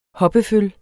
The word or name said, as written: hoppeføl